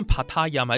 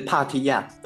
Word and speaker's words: Patya: 好，你们的这个打铁仔有一个英文，这是这念帕塔亚还是怎么念比较正确？ – 这是帕提亚，有点像是台语的打铁仔帕提亚，然后再把它用英文的方式给呈现出来，这样子。